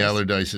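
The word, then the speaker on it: Allardice: Is that how you pronounce Allardyce? – It? – Allardyce. – All right. Yeah, Allardyce, right.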